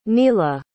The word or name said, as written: neala